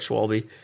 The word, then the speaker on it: Schwalbe: The S-C-H-V-W that makes like a V sound. Schwable. So, German listeners out there... Go ahead and send us a DM and let us know if I said it right. – Again, because obviously we didn't learn the first time. – Schwable. – Well, we call it Schwalbe.